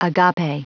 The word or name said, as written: agape